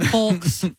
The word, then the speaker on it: folks: Folks. – What's wrong with that? And yolks. – It's mouthy. – Yeah. – You're mouthy. – Yeah, because you don't pronounce the L. You don't go for a walk.